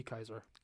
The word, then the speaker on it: Caesar: Has it ever bothered you that the Caesar's Legion pronounces Caesar Kaisar? – Kaisar, yeah. – You know that's actually how you would pronounce it in Latin? – In Latin? – It'd be Kaisar.